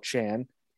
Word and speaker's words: Kajan: Found an interesting pronunciation about Mr. Yeah. Chan on Saturday, Pavel Chan. Even though it's spelt K-A-J-A-N, that pronunciation was provided by Mike Farwell.